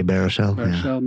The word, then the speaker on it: Baruchel: Burchell? – Jay Baruchel